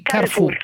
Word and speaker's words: Carrefour: Carrefour, la prossima volta, no Carrefour, di Carrefour. Carrefour. Quasi senza pronunciare la R finale, Carrefour.